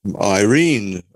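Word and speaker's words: irene (εἰρήνη): that's where we get the English name Irene, of course. And I know plenty about Irene, as some of you understand. It's actually pronounced irene, close to the Greek form.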